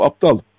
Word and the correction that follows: Abdal: Abdal ben yanlış e, telaffuzumu e, becerememiş olabilirim. Grup Abdal ama ne demek istediğimi anlıyorsunuz.